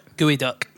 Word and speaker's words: geoduck: Again, we'll be very familiar to people from these places, but in places like Oregon and Washington State, there's, now it's spelled G-E-O-D-U-C-K. – G-E-O-D-U-C-K. – Gooduck? – Gooeyduck. – Gooeyduck. Yeah. – Weird, to start with, is gooeyduck, spelled completely the wrong way.